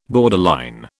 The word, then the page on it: borderline: British English